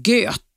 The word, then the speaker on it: Goethe: Göte Goethe stavas G-O-E-T-H-E e rätt.